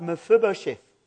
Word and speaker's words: Mephibosheth: In the Old Testament, there's this wonderful story about Mephibosheth.